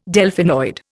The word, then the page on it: Delphinoid: Indian